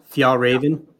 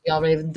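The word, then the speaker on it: Fjällräven: Fjallraven. – Fjallraven.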